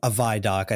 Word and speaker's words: ViDoc: Do you say ViDoc? VidDoc? – Yeah, so yeah, 10 a.m. they had a ViDoc. I think that's how the community says it, yeah.